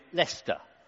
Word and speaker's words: Leicester: There's something even more astonishing to me about Leicester, uh, and that's that you can take these nine random letters, put them together like that, and try to convince me that you pronounce it Leicester.